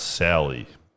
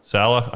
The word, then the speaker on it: Saleh: I think it might be pronounced Sally, but... – It says Saleh. – Saleh? All right.